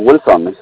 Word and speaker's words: Wilson: My name is Wilson Janes. – Okay, and how do you spell that? – Mr. Wilson, W-I-L-S-O-N. – Oh, Wilson, and okay. – Janet is Y-A-N-E-S.